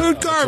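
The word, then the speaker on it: Utqiagvik: The name is spelled, you tell me how you would pronounce this, U-T-Q, IAGVIK. – Oh, Garvik. – Yeah, yeah, Garvik. Garvik. Yeah. – Ood Garvik. That's what I think.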